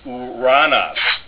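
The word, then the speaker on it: uranos (οὐρανός): Omicron upsilon, pronounced u, as uranos. Uranos, very important Greek form in the New Testament, meaning heaven.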